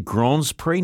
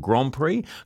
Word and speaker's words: Grands Prix: And I said Grands Prix, not Grand Prix, because that's how you say it.